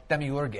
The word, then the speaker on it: Demiurge: Well, Demiurge is how you pronounce it.